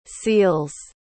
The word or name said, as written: seels